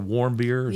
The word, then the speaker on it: Warmbier: I mean, we have the case of, I don't know how you say his last name, Otto Warmbier.